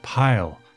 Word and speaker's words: Paal: and his name is spelled P-A-A-L. Now, being that his parents are German, I looked up the pronunciation and it seems to be that it's pronounced pile, but English speakers would generally look at it and think it's a play on the name of Paul.